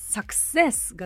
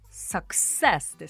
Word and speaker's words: success: サクセスが正しいアクセントですからね。サクセスですよ。